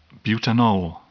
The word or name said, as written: butanol